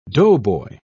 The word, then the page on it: doughboy: British English